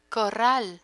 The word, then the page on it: corral: Spanish